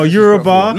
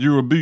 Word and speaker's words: Yoruba: No, Yoruba. – No. Yeah. At least he's pronouncing it correct this time. The last time he said Yorubu, you know. – Yeah. – Man said to me Yorubu.